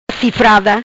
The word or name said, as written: Cifrada